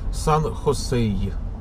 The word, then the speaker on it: Сан-Хосе: Сказали, значит, там четко слышится «х», значит, там сказали «Сан-Хосей», «Хосей», «Хосей», «И».